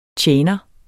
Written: tjener

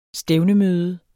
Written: stævnemøde

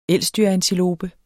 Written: elsdyrantilope

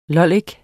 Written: lollik

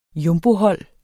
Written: jumbohold

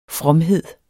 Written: fromhed

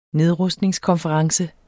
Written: nedrustningskonference

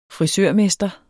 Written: frisørmester

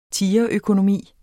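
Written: tigerøkonomi

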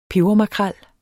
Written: pebermakrel

